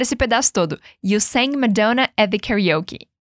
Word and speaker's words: Madonna: Ele cantou Madonna. Olha só como a pronúncia é diferente em português do inglês. Repete a pronúncia do inglês comigo. Repeat after me. Madonna.